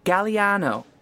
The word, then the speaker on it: Galliano: My surname's Galliano. – Can you spell that, please? – Sure. G-A-L-L-I-A-N-O.